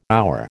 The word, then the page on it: hour: American English